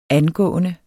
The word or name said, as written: angående